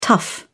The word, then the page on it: tough: British English